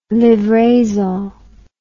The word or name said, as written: livraison